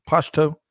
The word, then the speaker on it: Pashto: In this case, and I may get this wrong, a Pashto, I think, is one of the dialects in Afghanistan. Did I say that right, Greg? Pashto? – I think that's right, yeah.